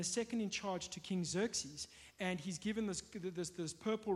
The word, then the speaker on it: Xerxes: But it's King Xerxes.